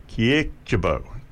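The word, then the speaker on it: Kyrkjebø: Surname is K-Y-R-K-J-E-B, O with a stroke through it. Kiekjebo, Sissel Kiekjebo.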